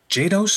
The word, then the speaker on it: Jados: Today, we have Aly Jados. Is that how you say it? Jados, yes. From Blood People, the scary band name that they are.